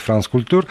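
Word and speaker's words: France Culture: которые высказали такое, знаешь, прям такую обструкцию Да, тут вот в, в, в газете «Франс Культ культур, наверное, так правильно говорить, Франц культур, э, даже такой образ.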